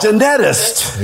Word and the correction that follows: geneticist: Geneticist, isn't it? – Geneticist.